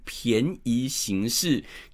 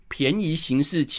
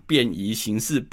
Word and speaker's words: 便宜行事: 便宜行事的生杀大权，讲到这边，先跟各位说明，很多人会把它念成便宜行事，其实不对的，因为这里的便宜行事其实是变方便的意思，所以是方便适宜情况来行事的意思，所以记得以后要讲便宜行事，不要给我念便宜行事哈。